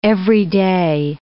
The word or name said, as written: everyday